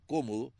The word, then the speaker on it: Cómodo: En el año 176 de esta era, el emperador Marco Aurelio le concedió a su hijo, que se llamaba Cómodo, así con acento en la Cómodo, el rango de imperator y comandante supremo de las legiones romanas.